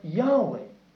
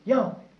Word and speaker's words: Yahweh: And we don't know exactly how that's pronounced. We believe that the vowel sounds were Yahweh. Yahweh. And that's the nearest we've got to it and the most generally accepted sound for the name Jehovah.